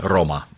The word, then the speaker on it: Рома: Хотя Рим – это Рома. В женском роде произносить надо. Вот она.